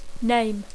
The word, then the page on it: name: English